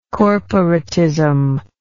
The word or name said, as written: corporatist